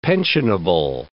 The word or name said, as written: pensionable